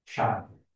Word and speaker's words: Sian: Shan is actually the female version. S-I-A-N. Shan.